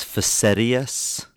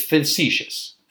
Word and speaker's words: facetious: I thought you were being, I think the word is facetious. – That's how you would pronounce it. It's facetious. Facetious.